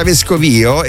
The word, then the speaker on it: Vescovio: Io lo sapevo che avendo pronunciato, se avessi pronunciato Piazza Vescovio così, con l'accento sulla I, giustamente qualcuno sarebbe rimasto un po' interdetto, ma anch'io ho sempre pronunciato Piazza Vescovio, questa piazza di Roma che si trova praticamente, non è alla fine di Via Nomentana, insomma, però di quel tratto lì di Via Nomentana. E Piazza Vescovio si pronuncia in questo modo.